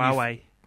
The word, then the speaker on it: Huawei: And Huawei, is that how you pronounce them? The Chinese menu? – Huawei. – What he said. Yeah, Huawei. That is how you say it.